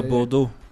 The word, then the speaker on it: Bordeaux: But then we've also seen there's a French club called I can't say the name properly, but like Bordeaux. – Oh yeah, Bordeaux.